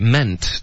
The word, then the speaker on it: meant: Actually mean your spare time. Do you cook? – Okay, meant 的发音不是 mean. Okay, 所以要注意不要发 mean. Okay, 是 meant, man.、嗯 okay.